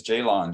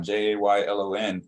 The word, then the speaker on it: Jaylon: My full first name is Jaylon, Jaylon. And growing up, no one had that name. Now you can see it's out there, but people still mispronounce it. They mispronounce that O in there like it's a Y and say Jalen, or like it's an E.